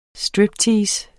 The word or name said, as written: striptease